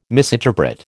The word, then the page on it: misinterpret: American English